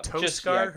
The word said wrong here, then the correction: Teoscar: Yeah, but is that how you pronounce it? Toscar? – Teoscar. – Oh, Teoscar. Because if it was Toe, it'd be T-O-E.